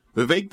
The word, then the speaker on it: Vivek: Vivek doesn't just rhyme with cake.